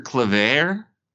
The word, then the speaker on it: Claver: And he trades Will Barton, Victor Claver. Is that how you say his name? – Yeah, that's right.